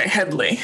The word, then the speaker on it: Headlee: Headlee, just like it's spelled. – Okay, Headlee.